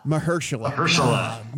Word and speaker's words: Mahershala: Mahershala? Is that good? – Mahershala. – Man, I was so close.